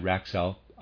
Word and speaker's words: Raxalp: Readers aside, Raxalp, I'm pronouncing that, it's R-A-X-A-L-P.